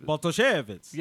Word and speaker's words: Bartoszewicz: You put an emphasis on the O, so you're like Bartoszewicz. – Bartoszewicz.